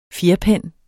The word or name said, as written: fjerpen